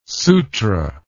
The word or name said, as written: sutra